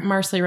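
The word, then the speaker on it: Marsali: Marsley Rhymes with Parsley.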